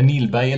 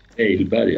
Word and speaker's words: Nailberg: Martin Gustafsson skriver, våren 1994 gjorde Bojnes 2 intervjuer med Kenneth, nu ska vi se, är det Nilberg eller Nailberg han uttalade? – Nailberg, ja.